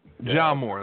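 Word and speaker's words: Ja Morant: Ja Moore. I think it's how it's pronounced. – Ja Moore, that's it.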